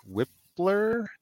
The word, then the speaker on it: Wypler: We're going to be looking at Ohio State center Luke Wypler. Did I say that right? – Yes.